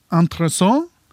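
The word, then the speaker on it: intéressant: For de kommer fra fransk. Og der uttales de, og nå må dere ha meg unnskyldt, «entressant» og observant. Altså ikke noe konsonantlyd på slutten i det hele tatt. Det er bare en veldig nasal A.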